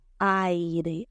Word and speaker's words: air: Es A, I, R. A i R. Pero lo que vamos a hacer es decir E, E, r E, e Air. E, es como si fuera una E y una R y ya. Air.